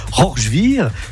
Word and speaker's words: Rorschwihr: C'est-à-dire que c'est tout neuf ce comité de jumelage avec Rorschwihr. Je prononce bien? – Tout à fait.